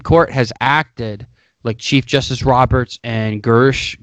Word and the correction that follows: Gorsuch: or whatever his fucking name is, because Gorsuch, thank you, Sean.